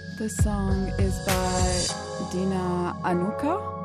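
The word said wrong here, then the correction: Anuk$a: Anuksa. So the way you spell it, D-E-N-A, and Anuksa is A-N-U-K dollar sign A.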